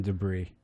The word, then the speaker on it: debris: I feel bad for the silent S in debris.